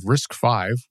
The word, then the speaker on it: RISC-V: The next bit of news is the addition of RISC-V, which is what Wikipedia says is the way to pronounce what I've always called RISC-V, as a supported architecture for CPython.